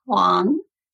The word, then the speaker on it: Huang: It's actually pronounced Huang. – Huang. Okay. – Like H-W-O-N-G.